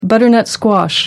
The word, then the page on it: butternut squash: American English